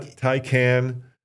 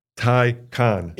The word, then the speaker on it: Taycan: Not Taycan, Taycan.